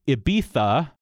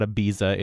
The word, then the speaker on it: Ibiza: It's spelled I-B-I-Z-A. This is Ibiza, sort of a TH sound rather than a Z. It is not Ibiza, it's Ibiza.